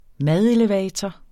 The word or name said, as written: madelevator